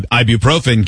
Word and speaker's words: ibuprofen: Ibuprofen.